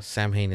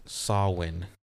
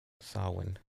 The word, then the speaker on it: Samhain: But yeah, Sam Hain and uh, even to go even further, uh, Sam Hain is actually pronounced Sawin.